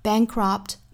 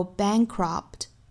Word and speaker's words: bankrupt: 那还要注意一点呢，就是 bankrupt 这个 p 的发音我们不是爆破十足的，并且也不要拖音加元音，不是 bankrupt，应该是 bankrupt pt, pt, pt, bankrupt，相当于只是上下嘴唇闭一下去摆这个 p 的口型，然后就很快过渡到后面的 t。 bankrupt by the age of 30 和 30 美音发音是 30。我下面再用非常慢的速度用美音来读一下。 We rip out so much of ourselves to be cured of things faster that we go bankrupt by the age of 30.